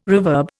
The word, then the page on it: reverb: Indian